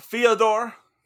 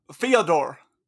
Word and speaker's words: Fyodor: Can you check me on that pronunciation? I know you're fluent in Russian. – Yeah, Fyodor. – Fyodor, okay. Fyodor. – No. Fyodor. – Fyodor. – Yep.